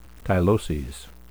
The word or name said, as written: tyloses